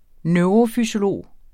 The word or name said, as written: NEUROFYSIOLOGER